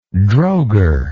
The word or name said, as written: drogher